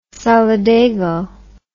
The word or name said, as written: solidago